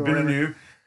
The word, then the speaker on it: Villeneuve: of Denis Villeneuve, however you pronounce his name. – Villeneuve.